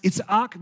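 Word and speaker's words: Itzak (יצחק): Isaac's name in Hebrew is pronounced Itzak because you separate all of the vowels, which sounds kind of like laughter.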